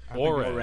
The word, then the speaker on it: Horry: You pronounce it the right way, then I'll go with it. – Horry. – Ore. – I think it's Ore. – Thank you. It's Ore. Ore. Okay, like Robert Ore.